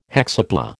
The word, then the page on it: hexapla: American English